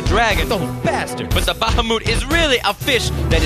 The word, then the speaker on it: Bahamut: So remember, they are pronounced Kujata, Kujata. and Bahamut. Bahamut.